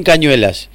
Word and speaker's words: Cañuelas: De repente saber que nosotros ahora el sábado vamos para Cañuelas y que hay gente que le queda lejos. – Bueno, pronunciaste, pará, hay un dato ahí importante, se ríen los chicos acá. Pronunciaste bien Cañuelas.